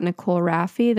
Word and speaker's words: Nicole Rafiee: It's just at Nicole Rafiee.